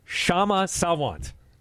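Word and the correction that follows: Kshama Sawant: I have been raked over the coals for mispronouncing that in Seattle. The K is silent. – We're not in Seattle. Yeah, it's Shama Sawant.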